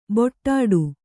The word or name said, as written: ಬೊಟ್ಟಾಡು